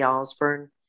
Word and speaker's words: Ausburn: You can find me on social media at Debbie Osborne. You just have to know how to spell my last name. It's spelled A-U-S-B as in burn your finger, A-U-S-B-U-R-N.